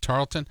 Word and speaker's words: Tarleton: Tarleton? Is that what it's called? Yeah, Tarleton.